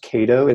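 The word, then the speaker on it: Caato: It's called, I think it's pronounced Kaito. It's C-A-A-T-O.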